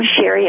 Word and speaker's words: Sharry: They're on Vimeo under my name, Sherry, S-H-A-R-R-Y.